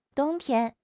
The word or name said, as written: dong1 tian1